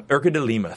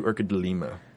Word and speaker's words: Urca de Lima: Or Urca de Lime. Urca de Lime. It's spelled Lima. But anyway. – Lima. – Urca de Lima.